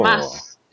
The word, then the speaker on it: Mads: I think it's actually pronounced, I was trying to read how it's pronounced, and you pronounce it like that, you don't pronounce the D. – So, oh, really? – So it's pronounced, I think, you know, like the end of Thomas. – Oh. – So Mass, just like the Mass bit, I think. So Thomas, Mass.